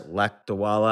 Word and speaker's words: Lakdawala: You mentioned a mutual friend of ours who does chess puzzles, Cyrus Lakdawala.